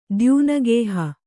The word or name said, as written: ದ್ಯೂನಗೇಹ